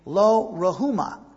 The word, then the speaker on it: Lo-Ruhama: And that one son, Lo-Ammi, that's how you pronounce it, which means not my people, and his daughter was given the name Lo-Ruhama. That's how you say it.